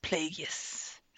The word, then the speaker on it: Plagueis: Darth Plagueis. Yes, I have to say it like the Emperor.